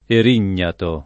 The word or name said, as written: erignato